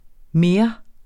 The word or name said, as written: mere